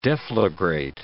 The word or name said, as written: deflagrate